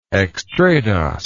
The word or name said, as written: extradosed